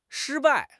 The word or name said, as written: shi1 bai4